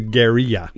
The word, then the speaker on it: guerrilla: The Spanish pronunciation, it was a guerrilla.